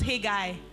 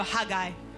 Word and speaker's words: Haggai: How do people pronounce Hey Guy? Is it Hey Guy or Haggai? What do you say? Depends on the anointing. If it's strong, it's Haggai. I say Hey Guy.